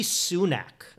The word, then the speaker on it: Sunak: Rishi Sunak. Am I pronouncing that right, Bill? Do we have any idea? – That's what I heard. Yeah, sure.